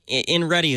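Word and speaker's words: Inrete: InReti is the company. – Okay. – And this is the Automatica. – Oh, you pronounce InReit InReti. I-N-R-E-T-E. I get it.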